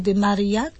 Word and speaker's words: de Marillac: I rozumiem, że to dzieło świętego Wincentego a Paulo oraz siostry Ludwiki de Marillac, tak dobrze wymawiam? – Tak.